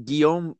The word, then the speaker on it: Guillaume: No, mate, it's actually French. It's pronounced Guillaume, but I usually go by my middle name, which is Paul.